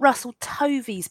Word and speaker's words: Russell Tovey: I'm not Russell Tovey's biggest fan. That is how you say his name, isn't it? – Yeah.